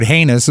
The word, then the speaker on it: heinous: I have always thought that one of the charms of the word heinous is the fact that it rhymes with anus.